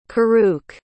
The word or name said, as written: kuruk